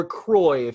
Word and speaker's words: croix: Spelled in the French way, C-R-O-I-X, quad candlestick. – Oh. – Or croy if you're using a Louisiana pronunciation.